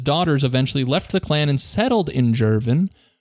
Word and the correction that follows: Girvin: It might be Girvin. I don't know. It's a G. I don't know if it's a hard G or a soft G.